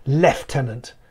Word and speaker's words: lieutenant: It's a British affectation, a British military affectation, that the word lieutenant, or as you say, lieutenant, is pronounced lieutenant.